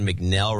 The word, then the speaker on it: McNell: And David McNell rhymes with Bell.